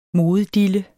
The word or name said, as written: MODEDILLEN